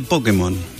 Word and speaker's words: Pokémon: y tiene un llavero de Pokémon. ¿Es Pokémon, Pokémon o Pokémon? ¿Cómo es el acento? – Y algunos dicen Pokémon. – ¿Pokémón? – Con acento en la O.